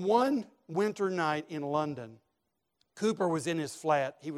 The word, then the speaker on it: Cowper: Some of you know the name William Cooper, spelled Cowper, C-O-W-P-E-R, pronounced Cooper.